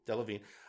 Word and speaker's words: Delevingne: And but the other thing I noticed for Cara Delevingne, I looked up the pronunciation. – Oh. – Delevingne. It was important. I wanted to get the name right. Based on her past work, Suicide Squad or Valerian, the getting to see what she can do when she's given good material.